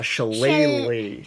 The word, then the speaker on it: shillelagh: Oh, Jessica also told us that um, the druid spell that you like to use so much um, is pronounced uh, shillelagh. Shillelagh. Like a ukulele.